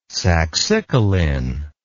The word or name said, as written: saxicoline